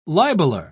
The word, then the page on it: libeler: British English